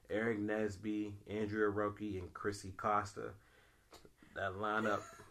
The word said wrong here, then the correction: Andreas O'Rourke: You said his whole name wrong. – What's his name? – Andreas O'Rourke. – What'd I say? – You said... – Andreas. – You said like Andrea Roki.